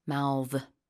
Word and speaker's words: mouth: So when it's a verb, it's a softer sound, mouth.